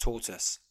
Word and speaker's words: tortoise: And I'm not sure whether you should say tortoise or tortoise, because it's spelt tortoise, but I believe it's pronounced tortoise. So I'll stick with tortoise.